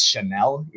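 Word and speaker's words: Chenal: I guess it's pronounced Chanel, even though it's not spelled Chanel.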